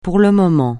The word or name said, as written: pour le moment